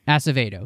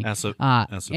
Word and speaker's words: Acevedo: And honestly, I think the other main impactive character is Kirk as Vado, I think is how he would say his name. Acevedo, excuse me. – Acevedo.